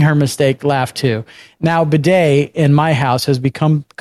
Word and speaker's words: bidet: She says, and next to the regular toilet is a bidet, except she didn't say bidet. She pronounced it boudet.